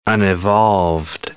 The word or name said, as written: unevolved